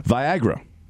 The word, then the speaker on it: Viagra: Viagra, this is a good one. – Oh, let's hear this one. – Oh, this is a good one, because it suggests vitality and vigor, and it rhymes with Niagara.